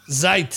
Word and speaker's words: Zeit: Zeit. – Zeit. – Zeit. Zeit. – Zeit. – Zeit. – There you go. – Zeit. – Okay. – We got Shite. Rhymes with Shite.